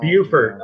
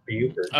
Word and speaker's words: Beaufort: Beaufort. I'm sorry, Beaufort.